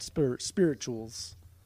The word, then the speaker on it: spirituals: I don't know, because the way it's spelled, T-U-A-L-S, but it sounds like chew, like choo-choo, chules, spirituals, spirituals. – Spirituals? – Yeah, I don't know.